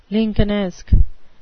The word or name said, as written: lincolnesque